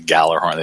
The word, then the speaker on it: Gjallarhorn: I think most people just call it the Gjallarhorn. They have a hard G there, but yeah.